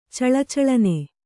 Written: ಚಳಚಳನೆ

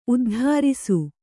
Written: ಉದ್ಧಾರಿಸು

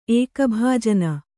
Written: ಏಕಭಾಜನ

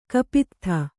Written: ಕಪಿತ್ಥ

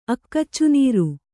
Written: ಅಕ್ಕಚ್ಚುನೀರು

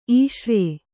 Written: ಈಷ್ರ್ಯೆ

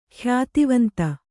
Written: ಖ್ಯಾತಿವಂತ

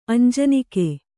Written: ಅಂಜನಿಕೆ

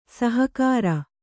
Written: ಸಹಕಾರಿ

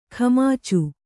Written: ಖಮಾಚು

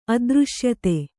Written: ಅದೃಶ್ಯತೆ

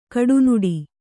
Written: ಕಡುನುಡಿ

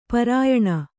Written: ಪರಿಯಾಣ

